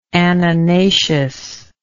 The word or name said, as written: annonaceous